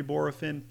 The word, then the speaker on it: Bohrofen: This is one that I've heard many different ways. Bohrofen, I've heard, but I'm pretty sure it is Bohrofen. Pretty straight, almost 2 syllables, closer to 3. Bohrofen.